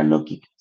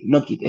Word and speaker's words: Knockit: Había una pregunta en Instagram que me preguntaba cómo se llamaba la empresa y la empresa se llama Nokit, se escribe K-N-O-C-K-I-T, Nokit, es como de knock knock, cuando en inglés se, eh, cuando uno golpea la puerta, eh, Nokit, a eso se refiere.